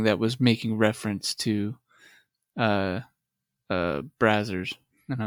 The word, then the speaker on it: Brazzers: I love how we always end up on like I just remember the episode where Victor brought up Brazzers. – Well, by the way, I finally did hear someone say that that's how it's pronounced. – Brazzers? – Yeah.